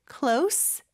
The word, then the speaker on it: close: 자, 그리고 참고로요, 나중에 발음할 때도 얘기는 하겠지만 close, C-L-O-S-E 이 단어가 발음이 크게 두 가지가 있어요. 하나는 close, S 발음, 그리고 마지막으로 하나는 close, Z 발음이 있죠. 근데 여기서는 close, S 발음을 꼭 해주셔야 돼요. 이게 S 발음이냐 Z 발음이냐에 따라서 품사가 완전히 달라지거든요. close, Z 발음으로 하면은 닫다 라는 뜻이 되죠. 그래서 보통은 뭐 예를 들어서 close the door, close your eyes 이렇게 뭐 눈을 감아요, 문을 닫으세요 이렇게 동사로 쓸 때는 Z 발음을 씁니다.